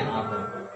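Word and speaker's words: Amr (عمرو): Nah kalau Ain Memro itu bacanya Umar ya kalau Ain Memro Wawu ini kan Ain Memro Wawu ya bacanya Amr ya di bidang integritas.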